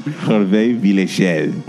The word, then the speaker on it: Hervé Villechaize: Hervé Villechaize.